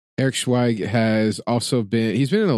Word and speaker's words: Schweig: Now, how are we saying his last name? I've actually never heard his last name pronounced. – Uncas? – Yeah, Eric Schweig. Schweig? Yeah.